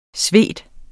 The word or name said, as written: svede